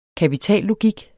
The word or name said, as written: kapitallogik